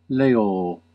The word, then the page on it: leō: /ˈle.oː/; Classical